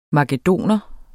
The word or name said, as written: makedoner